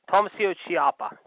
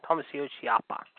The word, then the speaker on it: Tommaso Ciampa: Tommaso Ciampa. Tommaso Ciampa.